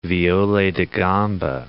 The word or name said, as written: viole da gamba